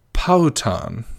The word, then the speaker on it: Powhatan: Let's find his pronunciation. Here we go. Powhatan.